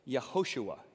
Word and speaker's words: Yehoshua: So in Hebrew, there's a name, Yehoshua, Yehoshua. And if you say it like 10 times fast, Yehoshua, Yehoshua, Yehoshua, your mouth almost wants to shorten. It's Joshua, Joshua, Yehoshua. So Yehoshua was often pronounced Joshua in our language, Joshua, which literally means Yahweh is my salvation.